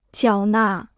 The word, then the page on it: 缴纳: jiǎo nà